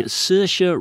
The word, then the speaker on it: Saoirse: Starring Saoirse Ronan, that is the correct pronunciation.